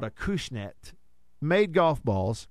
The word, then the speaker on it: Acushnet: A cushnet. A cushnet. But it's a Kushna? It's a it's a Kushnet. You That's, pronounce the T, yeah. – That, that, that is the correct pronunciation.